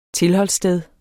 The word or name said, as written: tilholdssted